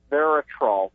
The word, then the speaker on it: resveratrol: Also, diabetics taking, is it called Resveratrol? What was that pronounced? – Resveratrol.